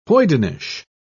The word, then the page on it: hoydenish: British English